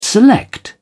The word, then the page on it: select: British English